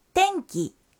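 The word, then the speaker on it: থ্যাংকি (天気): এই কাঞ্জির উচ্চারণ হল থ্যাংকি, থ্যাংকি।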